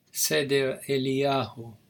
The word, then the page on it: Seder Eliyahu: Hebrew